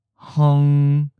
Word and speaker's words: hng: Some people may also pronounce the first word, far, distant, hng, s, hung. The first and the most common version, hng, you have a puff of air for h coming directly from your nose.